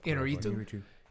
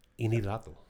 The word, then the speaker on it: Iñárritu: Iñárritu. Iñárritu. – Because the accent is over the I. That's the one that you're supposed to hit hard.